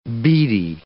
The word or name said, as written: beady